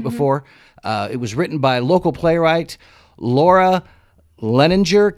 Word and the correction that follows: Leininger: Campbell. Is that right? – Leininger. – Oh, I knew I'd butcher her name. – You're so close, though. – Oh, Leininger. Sorry, Laura.